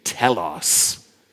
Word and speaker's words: telos: This word complete, here's how you say it in Greek, telos, to complete, to accomplish, to finish.